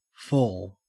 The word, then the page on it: fall: Australian English